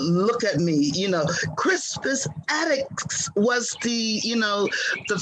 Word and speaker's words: Crispus Attucks: When I was in school, I was taught that Crispus Attuck, Crispus Attucks, yeah.